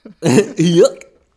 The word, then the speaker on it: Huyck: This episode was written by our goods good friends Alex Gregory and Peter Huyck. Say it right. Huyck. Thank you.